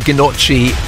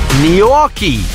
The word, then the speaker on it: Gnocchi: It looks like Ginocchi. It's pronounced Gnocchi.